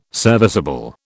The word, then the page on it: serviceable: British English